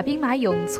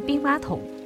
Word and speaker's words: 兵马俑: 谈到西安的历史古迹，就不得不提闻名世界的兵马俑。青衣，你对兵马俑感兴趣吗？ – 我对兵马俑挺感兴趣的。记得我第一次去西安的时候，才只有七八岁，什么也不懂。还总是把兵马俑错念成兵马桶。